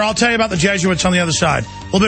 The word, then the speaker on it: Jesuits: No, it's spelled J-E-S-U-I-T. It's like, it's some type of like from the Vatican, Yo, Jesuits. – Jesuits, yes.